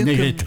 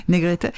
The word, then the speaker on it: Negrete: Je vous laisse chanter par un grand chanteur qui l'a popularisé, Jorge Negrete, mais prononcé mieux Negrete. – Que Negrete.